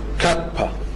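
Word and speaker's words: كابا (κάππα): الحرف اللي بعد كده حرف كابا، لو كتبتها بالانجليزي تبقى كده كابا، بي مش بي، تمام. بتتنطق زي حرف الكي بالظبط الانجليزي، وهي شبه تتنطق زي الكاف، تمام.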